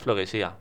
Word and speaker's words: Flowesía: Creo que es Flowesía o Flowesia. Yo creo que es Flowesía. Me decanto por eso. Sí, ¿no? Lleva tilde.